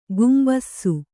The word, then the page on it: ಕುಂಭಿಸು: kumbhisu